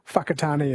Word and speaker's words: Whakatane: I think the WH is pronounced F, like Whakatane is WH, Whanganui WH instead of F.